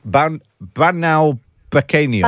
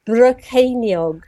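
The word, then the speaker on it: Bannau Brycheiniog: But how should we be pronouncing it? – Banau Brycheiniog. – Banau Brycheiniog. – Banau. – Banau. Banau. – Brycheiniog. – Brycheiniog. Banau Brycheiniog. – Yes, that's it. With a bit of a ch on the ch.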